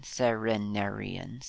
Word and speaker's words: Cerenerian: The Serenarian Sea is spelt C-E-R-E-N-E-R-I-A-N, Serenarian Sea.